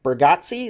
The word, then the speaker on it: Bargatze: Nate Bargatze? Is that how you say his last name? – That's right.